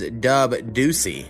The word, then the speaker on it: Dub Dueci: And that is Dub Deucey. – Dub Deucey! What up, motherfucker? – Now, that's the first time I believe I've pronounced his name correctly.